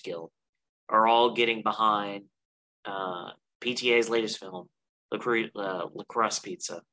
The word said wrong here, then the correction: Licorice Pizza: I know our good friend Nathan will correct me on the pronunciation of that. – Oh, it's Licorice Pizza. – Licorice Pizza, okay.